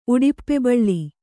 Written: ಉಡಿಪ್ಪೆಬಳ್ಳಿ

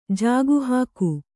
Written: ಜಾಗುಹಾಕು

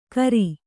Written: ಘೇರಿ

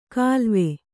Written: ಖಿಲ್ವ

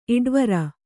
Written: ಇಡ್ವರ

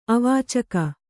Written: ಅವಾಚಕ

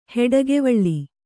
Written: ಹುಡಿಕೆವಲೆ

